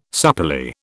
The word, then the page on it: supplely: British English